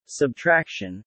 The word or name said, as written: subtraction